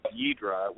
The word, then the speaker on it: Yidhra: And a lot of people ask me, like, they hear the band name, Yidra, which is spelled Y-I-D-H-R-A.